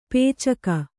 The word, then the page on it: ಪೈಚುಕೆ: paicuke